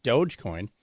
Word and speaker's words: Dogecoin: Except number two was Dogecoin. Dogecoin. Soft G. Dogecoin.